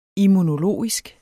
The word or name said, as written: immunologisk